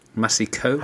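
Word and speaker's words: Massicot: Is it Massico? – Massico.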